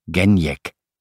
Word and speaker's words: Genjek: Als hij nou gewoon in Polen had gewoond, was er geen probleem geweest, maar nu moest hij iedereen steeds weer uitleggen dat het Gen was en dat de G werd uitgesproken als in het Duitse Gut en dan Jek, Genjek. Zijn collega's waren hem als eerste de Pool gaan noemen toen hij ruim 16 jaar geleden begon in de zeeploeg.